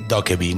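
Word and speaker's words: Dokkaebi (도깨비): Se escribe Dokkaebi, pero se dice Dokkaebi, ¿no? O sea, al menos por todos los coreanos que vi hablando del monstruo.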